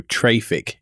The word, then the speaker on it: Traefik: It's spelled TRAEFIK, so traffic is kind of how it's spelt, but I think everybody just says it traffic.